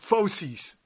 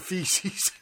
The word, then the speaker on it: fauces: You gave me this, how do you pronounce this? Fauces? – Fauces. – As opposed to feces.